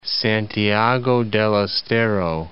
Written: Santiago del Estero

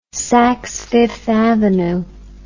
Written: saksfifthavenue

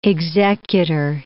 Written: executor

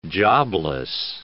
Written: jobless

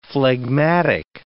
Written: phlegmatic

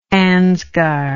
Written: ansgar

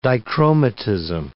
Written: dichromatism

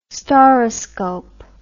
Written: stauroscope

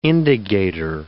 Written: indagator

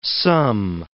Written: sum

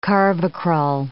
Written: carvacrol

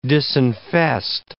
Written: disinfest

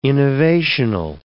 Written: innovational

